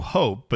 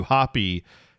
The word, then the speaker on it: Hoppe: We'll also talk about the new, people are calling him the new hope, but that's not how you pronounce his name, Matthew Hoppe, who is storming the Bundesliga right now for the U.S.